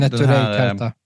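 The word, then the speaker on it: Neturei Karta: Och ett, man brukar ju använda också då Neurei Karta, för, ja. eller hur uttalas det? – Neturei eh, Karta.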